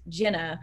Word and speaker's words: Jina: Obviously my name is pronounced Jenna, but it is spelled J-I-N-A.